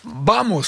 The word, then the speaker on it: Vamos: Vamos, ça se prononce comme ça. – Tout à fait. – Le V se prononce B en espagnol, je ne sais pas, je parle pas espagnol.